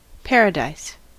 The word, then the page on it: paradise: American English